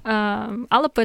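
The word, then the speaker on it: Alba: Алапа — это Шотландия, пишется Альба.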